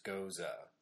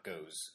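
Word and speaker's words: Gose: that's G-O-S-E, which is gosa, not goes or ghosts or however I wanted to pronounce it originally.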